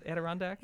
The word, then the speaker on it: Adirondack: Is it Adirondack? – Yeah.